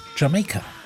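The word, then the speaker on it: D'yer Mak'er: Led Zeppelin in Bahn 1 Classic Rock und mit dem Song, obacht, Jamaica. Spricht sich nicht umsonst so wie die Insel Jamaica.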